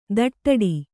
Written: ದಡ್ಡಿ

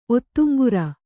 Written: ಒತ್ತುಂಗುರ

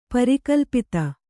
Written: ಪರಿಕಲ್ಪಿತ

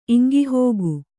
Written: ಇಂಗಿಹೋಗು

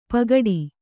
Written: ಪಗಡಿ

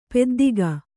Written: ಪೆದ್ದಿಗ

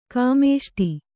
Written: ಕಾಮೇಷ್ಟಿ

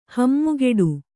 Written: ಹಮ್ಮುಗೆಡು

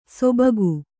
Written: ಸೊಬಗು

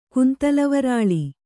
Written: ಕುಂತಲವರಾಳಿ

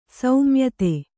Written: ಸೌಮ್ಯತೆ

